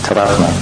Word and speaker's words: Tadatma: So, this identification process in Sanskrit is called Tadatma. T-A-D, long A, Atma, long A-T-M-A, Tadatma,